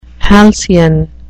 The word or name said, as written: halcyon